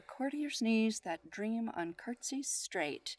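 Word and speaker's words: court'sies: It's spelled courtsies, but it's curtsies.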